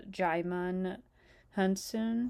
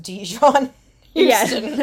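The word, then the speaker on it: Djimon Hounsou: Like, it looks like that, but it's actually pronounced Jaimin Hunsun. – yeah, no, I'm sure it's not pronounced Dijon Houston.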